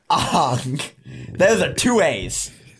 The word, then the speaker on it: Aang: Like Ong. Aang is pronounced Ong.